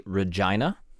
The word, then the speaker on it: Regina: Regina and your discomfort about how the correct pronunciation of that rhymes with vagina.